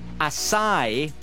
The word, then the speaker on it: acai: Well, apparently, acai, as in the berries, you know, the healthy stuff, A-C-A-I, that's how you say it, supposedly.